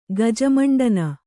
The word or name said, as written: ಗಜಮಂಡನ